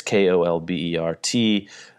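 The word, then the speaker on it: Kolbert: Elizabeth Colbert, I don't know, this Stephen Colbert has screwed me up for knowing how to pronounce that name. It's Colbert.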